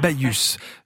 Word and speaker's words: Baïus: Baïus, B-A-I-2-L-U-S. – Baïus. Je vous ai laissé le prononcer comme ça m'évite de me faire gronder par Jean-Pierre Huguin pour pas avoir réussi à le prononcer correctement, on prononce le S, tout ça, voilà.